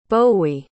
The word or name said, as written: bowe